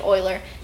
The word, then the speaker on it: Euler: named by a guy with a last name spelled like Euler but pronounced like Euler, who came from Switzerland.